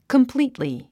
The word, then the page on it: completely: kəmplíːtli; コンプリィトリィ